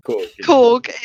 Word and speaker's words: Korg: Korg, yeah.